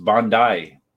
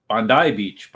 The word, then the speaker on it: Bondi: So it's pronounced Bondi, Bondi Beach, Bondi, Bondi.